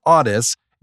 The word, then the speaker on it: ADES: It looks like it, it's spelled A D E S, but it's pronounced Audis.